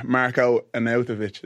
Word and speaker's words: Marko Arnautović: So at number three, I'm going to go for Marko Arnautović.